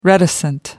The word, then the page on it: reticent: American English